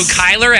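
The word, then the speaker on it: Cuyler: So Kyler Avenue, Beguile Brewing.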